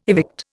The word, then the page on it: evict: Indian